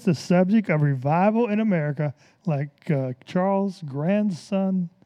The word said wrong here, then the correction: Grandison: Grandison, how do you say that? Grandison Finney, okay.